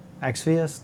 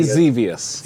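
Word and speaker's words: Xevious: How do you say this? Xvius? No. – I say Zevius.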